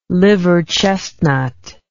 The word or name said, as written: liver chestnut